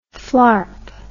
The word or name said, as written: flarp